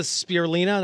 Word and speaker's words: spirulina: Does the spirulina, am I saying it right? – Yes.